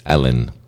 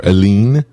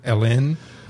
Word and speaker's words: Elin: Her name is Ellen. Or it's E-L-I-N. How would you pronounce that? – E-L-I-N. Ellen, I guess. – Or Aline? – Ellen. – Sure. Depends how you pronounce it.